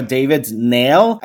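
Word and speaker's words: David-Néel: So the lady I'm referring to, she was named Alexandra David Nail. I think that's how it's properly pronounced. I actually listened to a few French videos to make sure.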